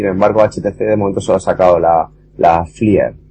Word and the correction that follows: Flyer: si eh, se pronuncia así. – No, Flyer, bueno, da igual, si lo de Gingerbread es que está basado en el kernel 26357, Android 2.3.